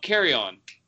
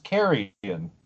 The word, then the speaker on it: Carrion: So you guys are pronouncing this "carry on" like luggage. – That's how Devolver and I pronounced it. – Are they British? – No. – I mean, like Dead Animals it's pronounced carrion. – Yeah, carrion, I know, but like they've said carrion.